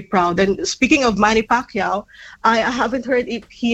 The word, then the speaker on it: Pacquiao: Manny Pacquiao, yeah, you said that right.